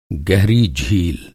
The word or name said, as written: गहरा